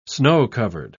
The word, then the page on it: snow-covered: British English